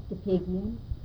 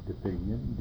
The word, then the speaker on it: DePigian: Susan DePiggin. – Susan, S-U-S-A-N. – D-E-P-I-G-I-A-N. – Richard, R-I-C-H-A-R-D, DePiggin, D-E-P-I-G-I-A-N.